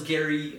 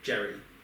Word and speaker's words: Gerry: And thank you, subtitles, for telling me that he spells Gary the different way, G-E-R-R-Y. – G-E-R-R-Y. – Yeah. – Jerry. – But he pronounced it Gary.